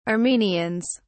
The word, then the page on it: armenians: British English